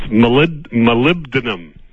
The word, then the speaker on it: molybdenum: Well, for all those that can't pronounce it, you short it to moly. – Moly. – But it's molybdenum.